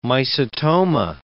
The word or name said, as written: mycetoma